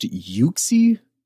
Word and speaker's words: Uxie: So Arceus also created the Lake Trio, these three lake spirits, which are, Uxie, Mesprit, and Azelf. I'm butchering names. – I think it's pronounced Uxie.